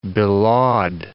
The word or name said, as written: belaud